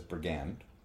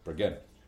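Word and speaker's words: Brighid: It has been pronounced as Brigand okay. with an N. – That is incorrect. That is white bread shit. Uh, but as Brigade.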